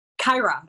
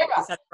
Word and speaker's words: Kyera: Kyera. It's perfect. Kyera.